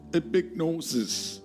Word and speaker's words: epignosis: Epignosis. Yeah, very nosy. Epignosis. E-P-I-G-N-O-I-O-S-I-S.